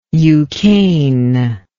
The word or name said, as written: eucaine